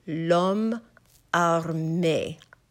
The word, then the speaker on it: l'homme armé: You will give more stress to the first part of the word, lom. Lom. Then the third time you say lom, you will not pronounce that E at all. It will just simply be lom arme. Ah, with a flipped R, me, with a very pure A sound. Then we have l'homme armé doit. That D-O-I-T is doit.